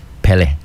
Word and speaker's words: Pele: So is it Pele? – Pele. – Pele. – Yeah.